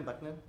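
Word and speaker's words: Bắc Ninh: I think, did I say that right? Bac Ninh? – Yeah, Bac Ninh.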